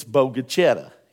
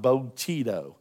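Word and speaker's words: Bogue Chitto: Now, if you don't know how to pronounce Bogachetta, it's Bogachetta. It's not Bogachetto. I know it looks like Bogachetto. It's Bogachetta.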